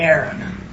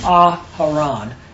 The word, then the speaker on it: Aharon: It's not Aaron. That's not the correct pronunciation of it. So it is Aharon.